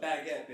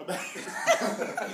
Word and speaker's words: baguette: A baguette, bitch. – A baguette. – You still saying it wrong. I'm the one that corrects you. You said a Bugatti. Yeah. What is a Bugatti? I'm like, a baguette? – Oh.